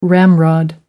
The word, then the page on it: ramrod: American English